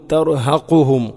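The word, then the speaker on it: തർഹഖുഹും (ترهقهم): അബ് സ്വാറുഹും തർഹഖുഹും തർഹഖുഹും ഖാഫ് തർഹഖുഹും അല്ല, ഓതുമ്പോൾ പലപ്പോഴും കാഫ് കൊണ്ടാണ് നമ്മൾ ഓതാറുള്ളത്. തർഹഖുഹും സില്ല, ദില്ലക്ക് പകരം പലപ്പോഴും സില്ല, ഇതൊക്കെ ശ്രദ്ധിക്കണം. തർഹഖുഹും ദില്ല.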